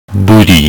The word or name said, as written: Buddhi